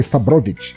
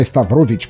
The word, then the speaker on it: Stavrovic: Eu estou resenhando a banda de Dara Stavrovic, a Natália me ensinou a, a, a pronunciar direito é Stavrovic. Não é Stavrovich, como eu vinha falando até agora, porque eu sou uma besta.